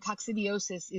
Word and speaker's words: coccidiosis: So that's very important, because I call it coccidosis, right? And that's the actual, like, the after effect of the coccidia How do you pronounce it? – Coccidia are the parasites, and coccidiosis is the disease.